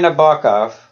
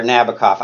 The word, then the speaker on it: Nabokov: So Vladimir Nabokov, or Nabokov, I realize I pronounce him in the Russian way, and often people even who know him very well can't tell who I'm talking about.